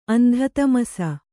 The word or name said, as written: ಅಂಧತಮಸ